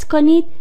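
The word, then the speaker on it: Knie: واژه بعدی کنی. دقت کنید باید حرف کا رو تلفظ کنیم. کنی.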